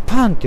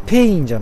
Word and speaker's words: pain: ンティーな店があるということでで今私が向かっているのはモノパンこれねモノパンって呼ぶと思うんですけどねローマ字で MORO あモロですねモロパンパンは PAIN これおそらくパンって読むと思うんですよねペイン